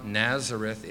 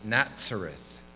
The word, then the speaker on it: Nazareth: But with a Sade, a letter that is pronounced T-S. It's not Nazareth, it's Nazareth.